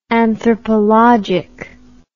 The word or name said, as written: anthropologic